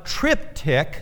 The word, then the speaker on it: triptych: Now, in art, a triptych, have you ever heard that term? A triptych, it's spelled T-R-I-P-T-Y-C-H, A triptych is a piece of art made up of three pictures or three carved panels.